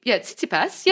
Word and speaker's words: Tsitsipas: Tsitsipas. Yeah, Tsitsipas. – Is it? What would you know? – Tsitsipas. – What would you know? – Tsitsipas. – Tsitsipas. Tsitsipas. – Tsitsipas.